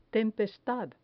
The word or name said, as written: tempestad